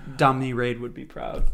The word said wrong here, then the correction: Domri: I probably butchered the pronunciation of that, but you understand. – Yeah, Domri. – Okay. – No, Domri, cool.